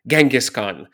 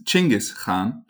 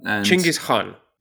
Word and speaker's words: Chinggis Khan: When I wrote to you with a list of proposed themes I'd like us to cover during this episode, I of course mentioned Genghis Khan, which apparently is not how we say his name at all. So why don't we start there? – Yes, so in fact, his name is Chinggis Khan.